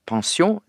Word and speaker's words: pensjon: Og vi har pensjon, eller pensjon. Pensjon, det er fransk, men eg meiner at på norsk så held vi oss til pensjon, vi uttaler ordet som vi skriver det.